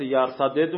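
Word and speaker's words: يرتدد: قرائت به صورت یرتدد اومده، به صورت فک ادغام اومده، دو تا دال در اون هم دیگه ادغام نشده، ما هم با همون صورت با تلفظ کنیم.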